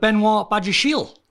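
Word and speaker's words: Benoît Badiashile: So Chelsea linked with Benoit Badiachile.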